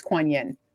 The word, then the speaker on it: Kuan Yin: It's spelled differently, but it's Kuan Yin.